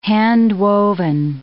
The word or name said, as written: handwoven